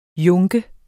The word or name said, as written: junker